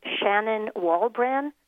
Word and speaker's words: Shannon Walbran: My name is spelled Shannon Walbran, W A L B R A N.